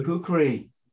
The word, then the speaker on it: kukri: So can we talk about the do I say it right? Kukri? – Kukri, yeah, Kukri. – Kukri, the legendary. – In Nepal, we said it's Kukri.